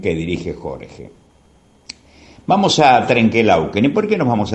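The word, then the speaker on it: Trenque Lauquen: Pero la historia es que Trenquelauquen, eh, Laguna Redonda o Lago Redondo, así eh, significa Trenquelauquen, cuya verdadera eh, pronunciación es Trenquelauquen, pero, pero con el tiempo las verdaderas pronunciaciones se popularizan dándole otra tónica.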